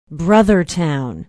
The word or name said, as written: brothertown